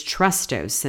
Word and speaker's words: chrestos: The Greek word used for kind in 1 Corinthians 13:4 is chrestos, and it's spelled C-H-R-E-S-T-O-S, which means to show oneself useful, to be kind, or to use kindness.